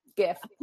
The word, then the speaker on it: GIF: You can write and you can add GIFs and you can add GIF. GIF, a hard G.